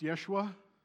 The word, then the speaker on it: Yeshua: In Aramaic, his name would have been pronounced Yeshua, all the Gospels are written in Greek.